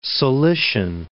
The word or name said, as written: Cilician